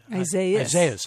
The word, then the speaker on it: Isaias: You take us to your great-grandfather once again, for those who read Towers of Gold, and his involvement in the wine industry, Isaiah Hellman, right? – Isaias. – I always forget that S.